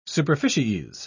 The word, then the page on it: superficies: British English